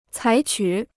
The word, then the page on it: 采取: cǎi qǔ